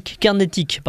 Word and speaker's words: carnatique: En Inde, il pratique le violon carnique, carnatique, pardon. Ouais, il me semblait qu'il me manquait une syllabe.